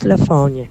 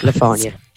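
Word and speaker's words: Le Fanu: It's pronounced Lafonia. The road in Ballyfermot. – Okay. – It is pronounced Lafonia.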